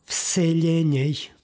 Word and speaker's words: vseljenjej: psalma imamo oblik vseljenjej. Izgovaramo dakle sa lj, jer imamo ekvivalent u srpskom vaseljena. Dakle, ovdje izgovaramo prema ekvivalentu u srpskom jeziku.